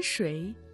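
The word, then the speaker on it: Шуй (水): Шуй – вода. В третьем тоне.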